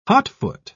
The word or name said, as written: hotfoot